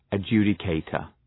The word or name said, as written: adjudicator